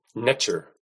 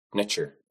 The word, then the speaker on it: Netter: Netter was actually pronounced netcher, extremely close to the English pronunciation of nature, netcher.